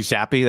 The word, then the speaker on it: Zappe: It's definitely Zappi. That's how you pronounce it.